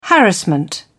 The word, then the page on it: harassment: British English